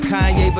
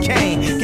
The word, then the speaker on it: Kanye: Now all I need is y'all to pronounce my name as Kanye, but some of my plastics still say Kane.